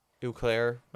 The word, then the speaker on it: Eau Claire: Eau Claire, yeah.